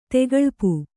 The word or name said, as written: ತೆಗಳ್ಪು